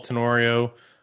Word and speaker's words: Tenorio: You can find me on Twitter at Paul Tenorio. T-E-N-O-R-I-O is how you spell that last name.